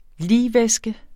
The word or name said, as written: ligvæske